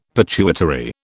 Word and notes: pituitary: British English

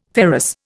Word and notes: ferrous: Indian

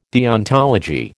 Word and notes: deontology: American English

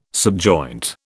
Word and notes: subjoint: British English

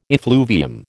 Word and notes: effluvium: American English

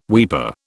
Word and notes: weeper: British English

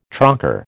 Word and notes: trocar: American English

